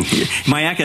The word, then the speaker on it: Myakka: Myakka.